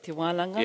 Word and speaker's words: Te Wananga: We stayed at Te Wananga, did I say that right? – Te Wananga.